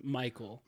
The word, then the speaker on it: Mykol: Michael. – Michael, and how do you spell it? – M Y K O L.